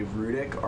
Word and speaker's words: Rudick: Rudick, R-U-Dick.